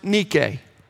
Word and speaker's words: Nike: Now we call it Nike, right? But Nike is the title of the Greek goddess of victory, though correctly pronounced Nike. But that's weird, so we'll stick with Nike.